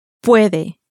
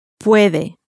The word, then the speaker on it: puede: It ends in a vowel, and so it receives its stress on the next to last syllable. He can. – Puede. Puede.